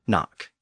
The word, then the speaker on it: knock: That includes words like need, K-N-E-A-D, and knee, K-N-E-E, and kneel, K-N-E-E-L, know, K-N-O-W, knife, K-N-I-F-E, knit, K-N-I-T, and knock, K-N-O-C-K. All of those words go back to Old English, and they were all once pronounced with an initial K sound.